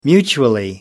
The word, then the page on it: mutually: American English